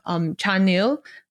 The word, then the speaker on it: Chanil: He's part of the photography club and he takes a lot of pictures of one of the members in his photography club, Chanil. How do you pronounce it? Like C-H-A-N-I-L.